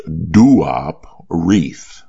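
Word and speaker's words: Duop Reath: His name is pronounced Duop Reef.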